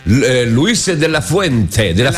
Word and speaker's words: Luis de la Fuente: Devo fare i complimenti al mister della nazionale spagnola, però Luis de la Fuente, ho detto bene perché mi sono levato i provvisori per dirlo, pensa.